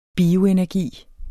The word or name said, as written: bioenergi